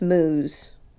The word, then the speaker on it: Meiwes: And it's just Muse. – Okay. – As a last name, because it's spelled like M-E-I-W-E-S.